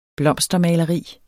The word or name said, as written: blomstermaleri